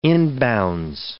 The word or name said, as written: inbounds